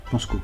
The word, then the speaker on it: Pensco: Penscos, je ne sais pas si je prononce bien. Pensco. – Pensco.